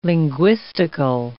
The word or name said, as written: linguistical